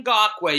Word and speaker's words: Ngakoue: Am I pronouncing that finally correctly? – Yannick Ngakoue, yes, sir.